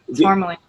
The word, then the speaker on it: Tourmaline: Yeah, Tourmaline.